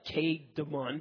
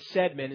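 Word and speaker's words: Cædmon: Looks like Cademon, but it's Sedmon is how you pronounce it.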